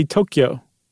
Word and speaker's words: Tokyo: Or I should say Tokyo. I've been saying it wrong. – Oh, it's not Tokyo. – Tokyo, it's Tokyo. Tokyo.